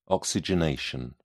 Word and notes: oxygenation: British English

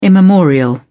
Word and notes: immemorial: British English

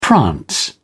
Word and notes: prance: British English

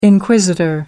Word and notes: inquisitor: American English

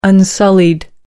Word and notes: unsullied: American English

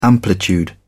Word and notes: amplitude: British English

